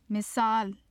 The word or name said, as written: misal